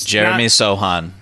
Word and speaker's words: Jeremy Sochan: Okay, that dude from Baylor, I don't know how to say his name exactly because it's Jeremy Sochan.